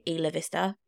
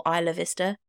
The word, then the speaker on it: Isla Vista: I think I'm saying it right when I say Ila Vista or Isla Vista killings.